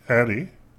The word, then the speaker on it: Ettie: And in our last book, we talked about Eddie, E-T-T-I-E. – Uh-huh. – And I was thinking Edie with a T. E-T. – E-T. I don't know how to pronounce that name. That is not a name that I've engaged before. So we went with Eddie, and the courtship of her father.